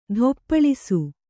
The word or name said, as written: ಧೂಪಳಿಸು